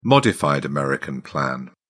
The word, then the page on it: modified american plan: British English